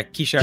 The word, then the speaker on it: Kishar: It's Kishar, is that how you pronounce it? – Yeah, Kishar.